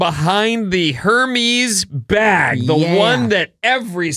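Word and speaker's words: Hermès: Didn't you just take a whole tutorial on how to Hermes? The guy was very good. He gave you two different pronunciations. Well, depending on your accent, you might want to go Hermes, or you could go like Ernest, Hermes.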